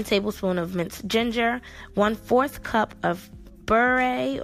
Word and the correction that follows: berbere: or bur, berbere, it's spelled B E R B E R E, which is an Ethiopian spice blend.